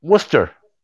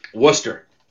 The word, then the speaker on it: Worcester: Worcester. – Worcester. – Worcester.